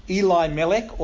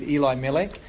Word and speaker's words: Elimelech: She's married, or she was married, to a man named Elimelech, or Elimelech. You can take your pitch which way you want to choose to pronounce his name. I'm going to go with Elimelech.